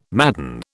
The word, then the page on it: madden: British English